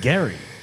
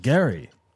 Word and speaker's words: Gerry: G-E-R-R-Y. But he pronounces it Gary.